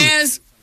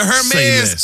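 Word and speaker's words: Hermès: Hermes? – Is that how you... See, I always hit the H. – The H is silent.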